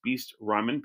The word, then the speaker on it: Beest: And it's spelled BEEST_Vancouver. And it's Beast Ramen, BEEST Ramen.